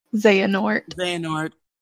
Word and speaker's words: Xehanort: Xehanort.